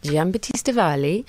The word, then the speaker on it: Giambattista Valli: Yes, it's, I'm going to have to try and say it right, it's Giambattista Valli, it's couture, and yeah, it's a lot of fabric.